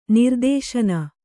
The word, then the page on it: ನಿರ್ದೇಶನ: nirdēśaba